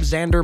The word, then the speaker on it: Xander: Also, while you're at it, go on Spotify or any one of your musical apps, look up Xander Marsden. That is spelled X-A-N-D-E-R, Xander Marsden.